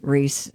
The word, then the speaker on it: Rhys: Does he pronounce it Rhys? – Rhys. – Does he? I don't know. – Oh, it is Rhys.